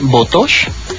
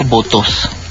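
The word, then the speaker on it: Botoš: Es doctor punto Juraj, así se escribe mi nombre, Botosh, B como burbuja, O como Otto, T como Teresa, O como Otto y S como seguro. Es como Juraj Botosh.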